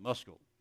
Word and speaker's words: Meskel: M-E-S-K-E-L, but they pronounce it Muskel.